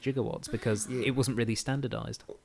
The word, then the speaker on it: gigawatt: this is an obscure but once standard pronunciation of gigawatt, which is a billion watts. It's usually pronounced with the hard G as in gander and gold.